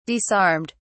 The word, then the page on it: disarmed: British English